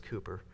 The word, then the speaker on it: Cowper: Spelled C-O-W-P-E-R. It's pronounced Cooper.